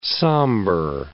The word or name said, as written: sombre